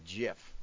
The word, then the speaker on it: GIF: And for what it's worth, he says GIF is pronounced with a soft G, GIF.